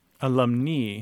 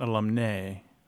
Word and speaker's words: alumnae: If they're only female graduates, then you want the feminine ending, which is A-E and is pronounced alumni. Okay. – Alumni, not alumnae, right? – Right.